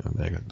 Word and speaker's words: Negga: Did I say it right? Negga? Okay. Negga. I'm sorry.